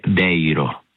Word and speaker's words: Deiro: Allora, Deiro o Deiro, come si pronuncia? – Sarebbe Deiro. – Deiro, allora l'avevo detto bene inizialmente.